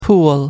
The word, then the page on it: pool: American English